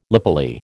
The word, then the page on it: lapilli: American English